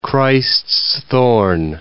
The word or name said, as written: Christ's-thorn